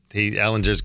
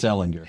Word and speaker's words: Ehlinger: I think it's Ehlinger. – If you have to ask, is he that important? – It's a hard G.